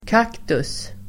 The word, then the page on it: kaktus: [k'ak:tus]